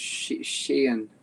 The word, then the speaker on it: Sheehan: You don't need to pronounce the H, just Sheehan. – Sheehan. – Yep, you got it. – I should have gotten this taken care of beforehand. My apologies. – That's fine. I think they do pronounce the H where the name is from in Ireland, but I don't.